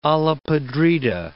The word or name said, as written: olla podrida